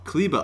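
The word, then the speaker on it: Kleber: Kleba.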